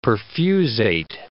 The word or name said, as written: perfusate